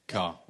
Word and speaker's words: Kerr: Car. Spelt like the vehicle. Yeah, okay. – Car. Well, that's not spelt like the vehicle. – It's spelt like the Kerr. – It's spelt like the Kerr, but it's pronounced like the vehicle.